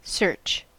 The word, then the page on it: search: American English